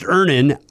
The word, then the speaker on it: Earnin: E-A-R-N-I-N is pronounced Ernin. I don't know how else you would pronounce it, but they really want it clear that that's how to pronounce it.